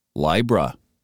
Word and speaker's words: Libra: And we scroll down to Libra, and it is pronounced Libra, I as in bind or time.